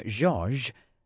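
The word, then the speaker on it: Georges: Or it was until the day Hurricane George, both ends of the word pronounced like the Z in Azure, scared most of the city into evacuating.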